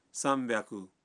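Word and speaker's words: سام پیاکو (三百): مثلاً 300 ہے سام پیاکو، 600 ہے روپ پیاکو، اور 800 کو کہتے ہیں ہاپ پیاکو.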